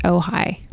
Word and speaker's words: Ojai: but the name is spelled like Ouija? – Really? – Yeah, but it's pronounced Ojai.